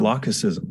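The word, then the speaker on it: lachesism: Is it lachism, lachism, lachism? – Lachicism. – Lachicism.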